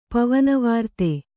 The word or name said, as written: ಪವನವಾರ್ತೆ